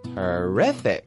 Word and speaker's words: terrific: 这一个词非常的简单，听好了，发音叫做 terrific, terrific, T-E-R-R-I-F-I-C, T-E-R-R-I-F-I-C。发音一定要注意了，它不念作 terrific, 重音在第二个音节，要念作 terrific。